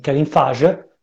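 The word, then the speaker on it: Kevin Feige: È un po' si muove come mm, Kevin Fage, questo si pronuncia così, ne sono sicuro al 100%,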